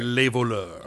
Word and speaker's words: Les Voleurs: An S, but you don't pronounce it. So okay. – It's Les Voleurs.